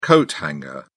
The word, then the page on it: coat hanger: British English